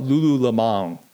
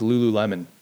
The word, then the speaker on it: Lululemon: You know, I always want to call it Lululemon. I want to give it like a little bit of panache, Lululemon. It just sounds like it should be pronounced that way. It looks like it should be pronounced that way, but it's just Lululemon.